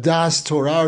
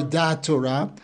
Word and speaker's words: Da'as Torah: This is going to lead us into a concept that's been controversial over the last several decades, that of Das Torah, or Da Torah. Even though I myself am Sephardi, I'm using the Ashkenazi pronunciation because it's more commonly used in conjunction with this concept of Das Torah.